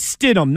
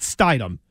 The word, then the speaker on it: Stidham: His name is Stidham, not Stidham.